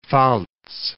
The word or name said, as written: Rheinland-Pfalz